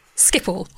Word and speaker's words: Schiphol: But I remember you mentioned a while back about your coverage in how do you pronounce it, Schiphol. Schiphol, the airport.